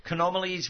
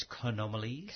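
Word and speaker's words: Chaenomeles: So Canomales, which is spelt with a CH, because apparently in Latin you're supposed to pronounce your CHs as a kurt, not a chirp. – Oh, right. – Yeah, so it's Canomales. Chimananthus, not Chimananthus. – Kalanchoe? – No, that's a K. – No, Coe, C-H-O-E.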